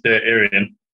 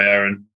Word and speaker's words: Aareon: So it's pronounced Aareon, but sometimes I go by Aaron, but in fairness, I've been called a lot worse before, so so you just seem to go with it.